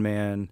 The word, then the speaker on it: Mann: But if you Google my name, Don Mann, Navy SEAL, that shows up anyways.